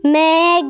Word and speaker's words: Megatherium: You say it like this. Megatherium.